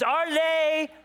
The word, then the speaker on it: Arles: Y'all like how I pronounce it with a French? Yeah, I could have said Arles, France. It's Arles, France.